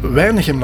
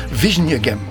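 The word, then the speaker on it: Wijnegem: Ik heb ooit eens een Dakarois, Weinigem laten uitspreken. Ik had opgeschreven Weinigem op een papiertje en die begon te lezen Vijnegem. – Ja. – Die kunnen de W niet uitspreken. Vijnegem.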